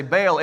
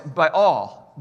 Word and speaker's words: Baal: And with Baal, you're not supposed to say Baal. It's Baal. Baal. That's how you're supposed to say it. But Baal's a lot easier and a more common word. So I'm going to say it like the average Joe Schmo says it and say Baal. But if you're around somebody that's like, wearing a bow tie, say Baal.